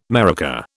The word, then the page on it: maraca: British English